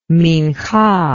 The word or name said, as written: minhah